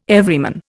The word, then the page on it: Everyman: Indian